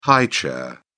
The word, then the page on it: high chair: British English